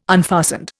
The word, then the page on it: unfastened: Indian